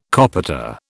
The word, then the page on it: copita: British English